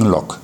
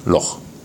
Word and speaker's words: Loch: Das Thema Loch, Loch Lomond, Loch Earn, Loch Ard wird tatsächlich mit CH ausgesprochen, auch durch die Schotten. Die können das nämlich. Die Engländer sagen Loch. Also Boris, bitte in Zukunft Loch.